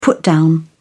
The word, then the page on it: put down: British English